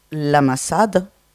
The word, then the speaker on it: Masada: C'est-à-dire que, en fait, cette salle, ça s'écrit la Masada, mais il n'y a pas un gascon qui le prononce comme ça. Voilà. Hein. Donc, ça s'appelle la Masada, hein, ce A reste suspendu. D'accord ? Le dernier, nous on n'accentue pas le A final, hein, dans certains autres coins d'Occitanie, mais Pierre vous en parlera.